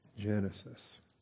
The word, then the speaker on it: Genesis: I'm pretty sure in Latin the G is a soft Genesis. In Greek, the gamma is a hard G. Anyway, by the time you get to English, it is a soft G, Genesis.